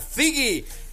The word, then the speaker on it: Zigi: Z-I-G-I, Sigi. – Ahí está, Sigi.